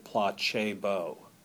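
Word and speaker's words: placebo: There is some Latin in this poem, as you know, from the church service, the Pla, he would have pronounced it Pla Che Bo, um, Pla Che Bo, I shall please, um, at the very beginning of the poem.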